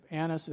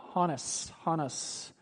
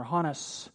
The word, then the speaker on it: Annas: And by the way, the correct pronunciation of Annas is like a H-O, Hannes, if you were going to say it in the right manner, Hannes.